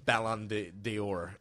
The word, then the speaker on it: Ballon d'Or: But it's called the fall on the floor. – Oh, the fall on the floor to rhyme with the Ballon d'Or.